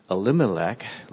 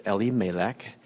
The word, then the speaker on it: Elimelech: And they pronounce it not Elimelech, like we often pronounce it in English, but Elimelech, with the emphasis on the L, being God, because the name Elimelech means God is my king.